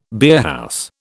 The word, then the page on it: beerhouse: British English